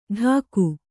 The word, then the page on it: ಡಾಕು: ḍāku